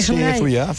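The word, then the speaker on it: Zuia: Es, ¿Lo he pronunciado bien o lo he pronunciado mal? – Zuya, sí, es el municipio donde vivo, bueno, donde viven mis aitas, mis padres, y donde me he criado.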